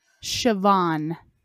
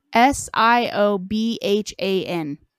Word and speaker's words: Siobhan: Have you seen how they spell Siobhan? – Shavakadu? – Siobhan. – No. – S I O B H A N. What? Siobhan is how you pronounce that.